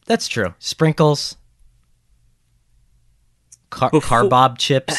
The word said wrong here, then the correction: carob: Carob chips.